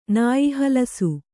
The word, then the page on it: ನಾಯಿಹಲಸು: nāyi halasu